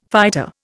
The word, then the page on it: fighter: Indian